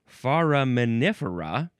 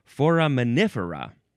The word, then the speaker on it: foraminifera: So that was foraminifer. This is foraminifera. All we did was add an A to the end, and it completely changed the emphasis of how to say this word. Foraminifera.